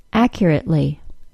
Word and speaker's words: accurately: If I take the adjective accurate and add the ly ending to it, making it into the adverb accurately, the T sound will be pronounced as a glottal stop.